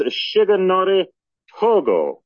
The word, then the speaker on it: Shigenori Togo: The spelling on that name is M-I-T-S-U-M-A-S-A, last name spelled Y-O-N-A-I, who was the Minister of the Navy in 1945, and Shigenori Togo. The pronunciation is Shigenori Togo, who was at the time The Japanese foreign minister to all of Europe.